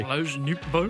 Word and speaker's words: Lars Nootbaar: Lars Nootbaar? Or Juan Yepes. I don't know why I didn't pronounce that correctly. – Lars Nootbaar.